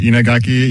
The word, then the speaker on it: Inagaki: I feel like I did that pronunciation pretty well. – You nailed it. Inagaki, yeah, you could say Inagaki Jiro.